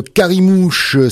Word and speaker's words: Karimouche: Elle s'appelle Carimouche. Ça s'écrit K-A-R-I-M-O-U-C-H-E comme la mouche.